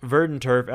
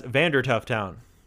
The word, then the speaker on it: Verdanturf: So it was also fun fact, for my almost entire adult life, I pronounced Verdanturf as Vandertuff Town.